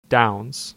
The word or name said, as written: Downs